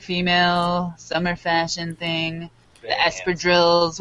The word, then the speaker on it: espadrilles: Yeah, espadrilles.